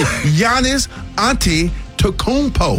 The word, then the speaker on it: Antetokounmpo: Well, the Milwaukee Bucks, they are back in the strong consideration of trading Giannis Antetokounmpo. – No. – Did I say it right? – Let me see. – Giannis Antetokounmpo.